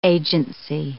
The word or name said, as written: agency